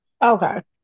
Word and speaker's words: Seneca: I think I'm pronouncing that correctly. Seneca. – I think you're right.